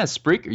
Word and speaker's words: Spreaker: Spreaker? Is that how you say it? – Yeah, Spreaker. You did good.